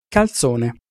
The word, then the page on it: calzone: Italian